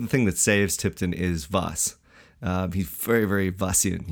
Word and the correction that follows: Vos: Um, Don't you mean Foss? Uh, yeah, Foss.